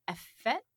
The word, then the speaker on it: effete: Entering movies in the late 50s as an effete, how do I say that? – Effete is how we say in English.